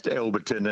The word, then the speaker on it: Alberton: Is it Alberton or Alberton? – Well, if it was Alberton, it would be A-double-L. – I would like to think that would be true. – It's actually pronounced Alberton, and it's just out of Beenleigh towards Jacob's Well.